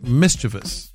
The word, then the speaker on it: mischievous: You pronounce it mischievous. It's not mischievous.